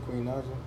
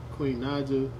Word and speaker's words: Queen Naija: Queen Naija. Queen Naija.